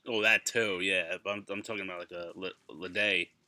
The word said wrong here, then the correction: bidet: right? Is that how you say it? How do you say it, Ladee? – A bidet? – A bidet, thank you.